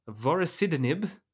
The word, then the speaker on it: vorasidenib: Josh, why don't you take us away on our final ride, one last ride, with Indigo, the study of vorasidenib, that's how you pronounce that word, in gliomas.